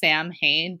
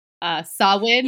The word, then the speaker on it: Samhain: So it's called the festival, and it literally looks like it's pronounced Samhain, but it's actually pronounced Samhain.